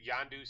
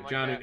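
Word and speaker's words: Jonnu: Yondu Smith? – Janu. It's Janu. It's Hard J. Hard J. – Janu Smith? Is that it? – Janu Smith, yes.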